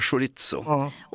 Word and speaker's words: chorizo: Så det är tjejljud fram och så är det hårt S på mitten. Chorizo.